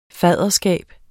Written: faderskab

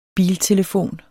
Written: biltelefon

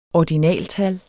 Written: ordinaltal

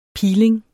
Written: peeling

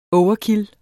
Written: overkill

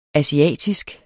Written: asiatisk

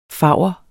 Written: favr

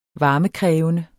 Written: varmekrævende

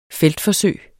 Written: feltforsøg

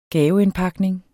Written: gaveindpakning

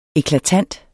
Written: eklatant

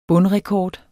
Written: bundrekord

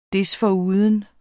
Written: desforuden

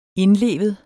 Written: indlevet

